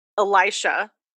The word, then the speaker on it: Elisha: Elisha Kane. – Elisha Kane. Of course it was. – It's spelled like Alicia like almost.